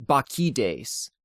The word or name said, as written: Bakchides